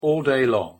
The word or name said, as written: all day long